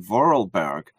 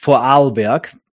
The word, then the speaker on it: Vorarlberg: I know that there are many people in Vorarlberg who completely agree with you, but are, of course, not as affected as the Viennese or the Tyroleans. – Yeah, it's by the way, it's pronounced Vorarlberg.